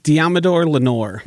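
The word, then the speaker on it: Deommodore Lenoir: How do you pronounce this defensive back's name? – Deamador Lenore.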